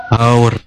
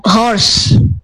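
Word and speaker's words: hour: Okay, jam so when it's related to time, so actually this should be pronounced with our. – Our. – The H is silent.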